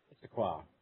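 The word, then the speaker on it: Issaquah: Isaquia, Washington. I don't even know how to pronounce this word. – Oh, that's pronounced Issaquia.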